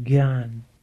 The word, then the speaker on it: Gnana: Of course, Gnana is the word in modern Hindi pronounced gyan,